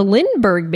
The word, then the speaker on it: Lindbergh: The Lindenberg baby? – The Lindbergh baby.